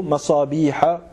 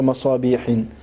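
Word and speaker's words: مصابیح: فهو ممنوع من الكسر ومن التنوين ف يعني ف يعني ننطقه ب ننطقه مصابيح وليس مصابيح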